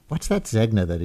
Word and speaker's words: Zegna: I was reading it phonetically as Zegna. Uh, I'm told by people much more familiar with uh, runways, I guess, than I am, that it's Zegna.